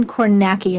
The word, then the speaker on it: Kornacki: It's written by you, illustrated by Christine Kornacki. Am I pronouncing her last name correctly? – Yes.